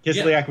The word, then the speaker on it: Kislyak: So, not 24 hours after all of this shit went down, Trump then meets with Lavrov, and then also, is it Kislyak, correct? Is that how you pronounce it? Was Kislyak there? – Yep, Kislyak was there, yep.